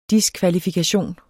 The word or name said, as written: diskvalifikation